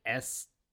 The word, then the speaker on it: estaminet: It's French, and you don't pronounce the T. And, like, I'd never heard that. That it's basically a French word for a cafe. A French word for a cafe is an estamin.